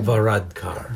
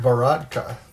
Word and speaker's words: Varadkar: And that's exactly what Prime Minister Leo, how do you say his last name? Varadkar. – Varadkar.